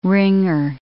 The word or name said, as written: ringer